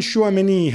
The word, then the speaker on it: Tchouaméni: Aurélien Tchouaméni has, my pronunciation okay? – Yeah, very good.